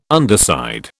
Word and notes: underside: British English

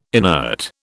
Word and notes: inert: British English

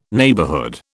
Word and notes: neighbourhood: British English

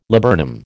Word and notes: laburnum: American English

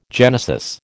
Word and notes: genesis: American English

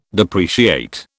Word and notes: depreciate: British English

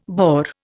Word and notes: BAOR: Indian

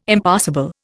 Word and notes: Impassable: Indian

